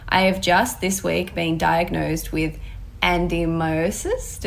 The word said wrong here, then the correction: adenomyosis: Did I say that correctly? Andimiosis? – It's such a tricky word. I only just recently learned how to pronounce it, adenomyosis. – Okay, yep, that's definitely better.